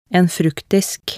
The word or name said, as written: en fruktdisk